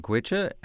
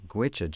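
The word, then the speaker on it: Goitse: And the band is called Gwicha, and this comes from their self-titled CD.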